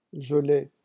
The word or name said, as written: geler